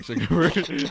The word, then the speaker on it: Zuckerberg: Mark Zark- Zuckerberg, whatever his fucking name is. – Yeah, Mark Zuckerberg.